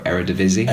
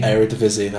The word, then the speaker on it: Eredivisie: I can't really pronounce the league name very well. Eredivisie. – Eredivisie, that's the one.